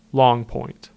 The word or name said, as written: Long Point